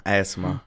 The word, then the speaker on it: ASMR: ASMR.